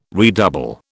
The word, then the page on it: redouble: British English